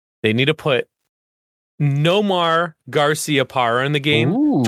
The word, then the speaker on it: Nomar: Pez, you say it. – Nomar. – That's how it's said.